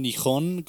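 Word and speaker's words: Nihon (日本): Sí, Japón, Nihon, como se, produce, se, pronuncia en japonés, significa igual, este, libro básico, eh, significado eh, básico.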